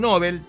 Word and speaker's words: Nobel: En la lengua seca, el apellido Nobel tiene pronunciación aguda. Nobel. En español, quizás para evitar la confusión con el adjetivo Nobel, también aguda. Se pronuncia en este caso como grave. Nobel. Pero es preferible la forma original y, sobre todo, respetar la pronunciación siempre.